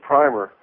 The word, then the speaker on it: primer: And according to this book I have here, it's called The Jewish Primer, and it's written by... – The Jewish Primer? – A primer? – Yeah. – Okay. – Just keep in mind, rhymes with rimmer.